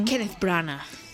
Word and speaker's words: Kenneth Branagh: Eh, a peli de Kenneth Braham, non sei se estou pronunciando ben. Kenneth Branagh.